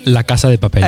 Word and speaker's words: La Casa de Papel: Come si pronuncia correttamente? – La Casa de Papel. – Ecco, come l'ho detto io prima? – Com'è? – La Casa de Papel. – Sì, ci siamo, secondo me.